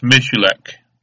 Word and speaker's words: Miculek: And M-I-C-U-L-E-K. It's pronounced Michalak.